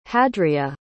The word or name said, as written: hadria